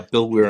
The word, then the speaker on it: Weir: Yeah, Bill Weir on CNN.